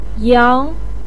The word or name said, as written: yang2